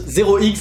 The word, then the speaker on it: 0x: Salut les investisseurs malins, aujourd'hui je vais te parler de ZRX ou OX, mais ça se prononce 0X, ZRX.